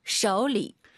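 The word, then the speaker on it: 쇼리 (手里): 쇼리. – 왜냐면 이 리가 삼성이에요, 원래. 그래서 삼성, 삼성이니까 앞에가 이성, 그 다음 반삼성. 니하오, 쇼리 이런 발음이 나온 거예요.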